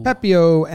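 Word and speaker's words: Pepiot: Pepio and Johnny DeLuca. – Oh, you didn't need to ask how to say that one, huh? – Nope. I'm not French like Trev. Is Pepio French?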